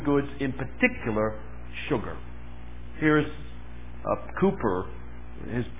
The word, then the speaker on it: Cowper: His name's spelled Cowper, but pronounced Cooper.